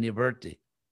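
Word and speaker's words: nivṛtti: I won't go into all, you know, the very long list of, of similar words, but actually, you know, we say prabriti because uh, I'm just pronouncing it the way everybody does, but the real Sanskrit pronunciation is pravrti, pravrti, and nivrti.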